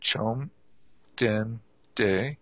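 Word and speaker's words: chom den de (བཅོམ་ལྡན་འདས): The equivalent Tibetan term chom, it's pronounced chom den de.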